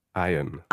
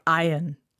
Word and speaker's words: Ion: Arcwave Iron. – Ion.